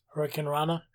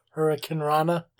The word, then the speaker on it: Hurricanrana: How do I say it? Hurricane. – Hurricanrana. – Hurricane. – Hurricanrana. – That's it.